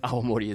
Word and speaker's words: awamori: And that's one of the theories behind why awamori is called awamori. You got to pronounce that W. If you don't pronounce a W, nobody understands what you're talking about.